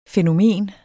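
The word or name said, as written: fænomen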